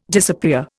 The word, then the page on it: disappear: Indian